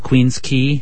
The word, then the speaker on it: Queens Quay: It's called Queens Quay. – Queens Quay. – Yeah, road. But some of you who speak phonetically would say Queens Quay.